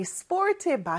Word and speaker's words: sport: 이 sport의 마지막 T 발음 너무 세게 되지 않게 T 이렇게 살짝 얘기하는 거 연습 많이 해주세요.